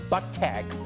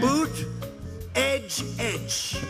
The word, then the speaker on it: Buttigieg: And it's definitely not Buttag. – Peter Buttag, the mayor of Indianapolis. – Boot, edge, edge. Say edge like the edge of a building.